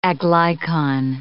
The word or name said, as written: aglycon